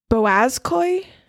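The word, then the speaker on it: Boğazköy: But the extra biblical text that they're talking about is the Boazkoi okay. – archives. Okay. It's spelled BOGAZKOY.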